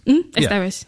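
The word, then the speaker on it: Estévez: By Antonio Estevez? – Estevez. – Yeah. You say it much better than I do.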